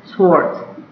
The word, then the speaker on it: Schwarz: Schwartz. – Spell that for me. – S-C-H-W-A-R-Z.